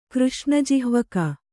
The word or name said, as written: ಕೃಷ್ಣಜಿಹ್ವಕ